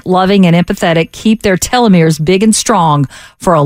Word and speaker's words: telomeres: Telomeres.